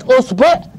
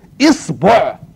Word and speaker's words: إصبع: يبقى كلمة أصبع انطقها على أي وضع شئت وزيد إيه؟ وزيد يبقوا كام؟ يبقوا عشرة تقول إيه؟ أصبع أصبع أصبع إصبع إصبع إصبع انطقها زي ما تكيف على كيفك تبقى تديني كام إيه؟